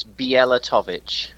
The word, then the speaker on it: Bielatowicz: Can you pronounce your last name for me? – Yeah, it's Bielatowicz.